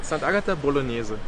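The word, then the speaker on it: Sant'Agata Bolognese: Am I saying that right? St. Agata? – St. Agata Bolognese. – Bolognese. – Yeah. In the Germans always say St. Agata Bolognese, but the Italians say St. Agata Bolognese.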